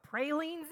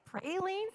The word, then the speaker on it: praline: I said, I think you mean pralines. The correct pronunciation is praline." And he said, "No, I'm pretty sure it's a praline." After 13 years of marriage, I can tell you that both are good. Both are correct. They're different, but both are good. How do you say it? L-A-F-A-Y-E-T-T-E.